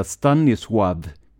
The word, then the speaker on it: Stanisław: Han heter Stanisław Gonerka och jag ska säga med en gång att L i hans förnamn är det jag kallar för ett polskt L, det vill säga ett L med ett snedstreck igenom. Enligt Wikipedia ska det uttalas citat ”som ett halvgutturalt engelskt W”.